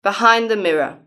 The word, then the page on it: behind the mirror: British English